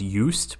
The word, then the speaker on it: Just: His name, I'm sure it's it's pronounced Eust,